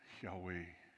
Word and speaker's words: Yahweh: it's actually said in the breath, Yahweh.